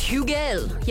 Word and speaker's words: Hugel: There's a lot of deliberation on how to pronounce this man's name. Is it Hugel? Is it Hugel? Apparently, it's Hugel. You have to put on the French accent for it.